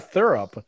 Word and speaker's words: Thurop: His first name is Thurup, like T-H-U-R-O-P. Thurup. Thurup. It's like saying the word syrup, but with like a TH sound. – Thurup. – Thurup. – Thurup. Excuse me. Can you path the thurup? – Can you path the maple thurup? So that's the best way to pronounce his name.